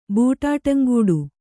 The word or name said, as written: ಬೂಟಾಟಂಗೂಡು